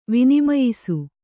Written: ವಿನಿಮಯಿಸು

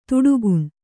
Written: ಥಟಕ್ಕನೆ

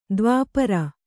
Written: ದೈವಾಪರೆ

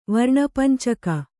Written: ವರ್ಣಪಂಚಕ

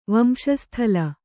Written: ವಂಶಸ್ಥಲ

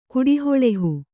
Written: ಕುಡಿಹೊಳೆಹು

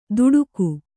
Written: ತುಡುಕು

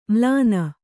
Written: ಮ್ಲಾನ

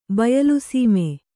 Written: ಬಯಲುಸೀಮೆ

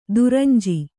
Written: ದುರಂಜಿ

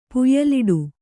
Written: ಪುಯ್ಯಲಿಡು

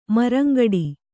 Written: ಮರಂಗಡಿ

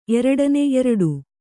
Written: ಎರಡನೆ

